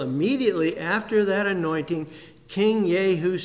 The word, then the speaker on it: Jehu: When the prophet Elisha was directed to anoint Jehu, J-E-H-U, Jehu, I think that's pronounced properly. Always reminds me of Yahoo, but it isn't Yahoo, it is Jehu.